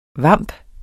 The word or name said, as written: VAMPENE